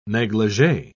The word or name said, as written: negligee